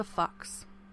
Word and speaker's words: Fuchs: My next note just says Hilda Fox. Fox is spelt F-U-C-H-S.